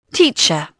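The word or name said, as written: teacher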